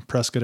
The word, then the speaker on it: Prescott: in um, Prescott, Arizona.